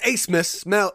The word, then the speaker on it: Abmas: Max Asmus, spelled A-B-M-A-S, put some respect on his name, even though it doesn't make sense when you look at the spelling versus how it's said.